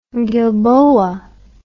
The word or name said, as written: gilboa